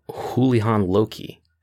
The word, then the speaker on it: Houlihan Lokey: Speaking of the XFL, according to Axios, Axios reported last week Thursday that investment bank Houlihan Lokey, I'm pronouncing that right, Houlihan Lokey, not to be confused with the former Ring of Honor world champion, is managing the process to sell the XFL.